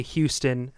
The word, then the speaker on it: Houston: It's like there's a street here called Houston in New York, and people call it Houston. – Houston? – Houston. Yeah. It's spelled like that, and people will say Houston, and then if you say that, if people say that, New Yorkers are like, it's Houston.